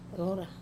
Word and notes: rora: wamesa

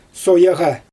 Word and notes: sojaɣa: Xyzyl

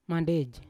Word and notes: mandej: Yawa

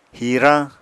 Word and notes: hirañ: Apatani

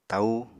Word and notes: taú: apatani

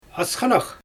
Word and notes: asxanax: Xyzyl

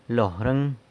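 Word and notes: lorëng: San Lucas Quiaviní Zapotec

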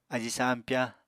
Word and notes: ají sampyá: apatani

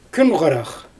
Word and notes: kün xarax: Xyzyl

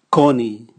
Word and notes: koni: Raga

